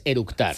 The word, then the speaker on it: eructar: y lo primero que hizo es orutar. – Con orutar es eructar. – Sí. ¿Vale? Se dice ¿cómo se dice? – Eructar.